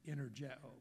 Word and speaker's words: energeo: That word works is the word energeo, pronounced, well, that's the southern version of the original Greek language. But it really is energeo.